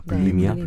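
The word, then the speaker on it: bulimia: 그 불레미아 너보자라고 하나요? 그러니까 네. 막 먹고 일부러 손을 넣어서 일부러 토하는 거예요. 음. 불리미아, 불리미아, 네, 불리미아.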